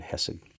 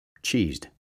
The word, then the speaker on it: Chesed: Unfortunately, the way it's spelled, people think it says cheesed because it looks like C H E S E D. Looks like cheesed or whatever. Chesed. They don't know. They don't know how to pronounce that part of it.